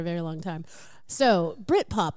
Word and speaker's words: Britpop: I think we need to say it like one word. – Britpop.